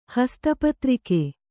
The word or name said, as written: ಹಸ್ತಪತ್ರಿಕೆ